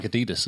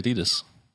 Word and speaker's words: Adidas: It's like Adidas, isn't it? But it's not really. No one really says that. – You mean Adidas. – Exactly. But all Americans say Adidas.